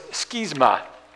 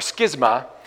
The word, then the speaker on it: schism: The word there, the Greek word, is schisma or schisma. We have a word in English that comes from that. It's alternately pronounced schism or schism. In America, it's usually schism, but in Britain, it's usually schism.